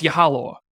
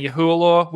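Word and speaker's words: Yehualaw: I think it's Yahaloa is how you say her last name. I've been saying Yahualoa.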